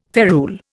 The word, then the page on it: ferule: Indian